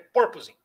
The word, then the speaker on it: porpoising: Por, proposing? – É, eu estava falando errado, mas a, a, a, a, a, a ênfase, é como se o acento circunflexo fosse na primeira sílaba. É porpoising.